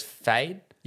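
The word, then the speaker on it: Feyd: I genuinely do think that it deserves acting noms for Austin Butler as Fade.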